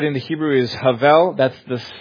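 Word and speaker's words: hebel: So it's H-E-B-E-L, and it's pronounced havel. Havel, it occurs in this book, 12 chapters, 38 different times.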